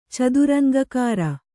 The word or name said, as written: ಚದುರಂಗಕಾರ